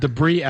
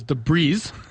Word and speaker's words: debris: You've mispronounced the word debris as debris.